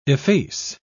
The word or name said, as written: efface